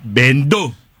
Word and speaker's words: Vendo: Vendo. – Oh, Vendo. – Pati pronunciation mali. Yung V naging B.